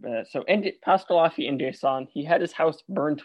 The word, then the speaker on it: N'Guessan: It's N-G-U-E-S-S-A-N. I've heard it pronounced N'Guessan and Nesson. I will be saying Nason because I hear that used more often. Or sorry, I'll be using N'Guessan because I will actually.